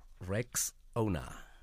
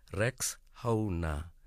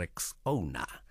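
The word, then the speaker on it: Rexowner: A friend even called it Rex Honor, but guys, it's Rex Owner, like Rex Owner, Rex Owner.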